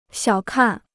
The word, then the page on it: 小看: xiǎo kàn